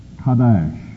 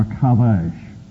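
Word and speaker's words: qadash: It would be pronounced Kadash or Kadash.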